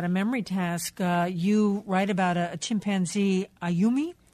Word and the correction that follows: Ayumu: Am I pronouncing? – Ayumo, I believe, yeah. – This chimpanzee who looks like has a photographic memory. Mm-hmm. What can Ayumo do? – So Ayumu can look at a computer screen on which 9 numbers appear and tap them in the right order.